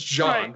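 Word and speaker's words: Jean: I knew it was John, right? I knew it was Lafitte. I didn't know how to pronounce, apparently, Gene or John in French. So I said Gene Lafitte in a video one time, and it has been a running gag on my channel for the past 4 years.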